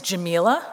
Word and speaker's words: Jamila: For those who don't know me, my name is Jamila, rhymes with tequila, and I have two announcements for you today.